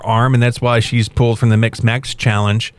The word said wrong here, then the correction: Mixed Match Challenge: Did I say that right? Mixed Match Challenge. – You said it right the second time.